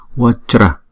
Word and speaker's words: Vajra: Vajra, Vajra, Vajra. Nào, Vajra. Hai âm Va. Chra. Chữ J này chúng ta đọc là J, J. Cho nên chúng ta rap lại là Jra, Jra, Wat, Jra, Wat, Jra.